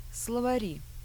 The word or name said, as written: slovari